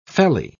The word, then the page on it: felly: British English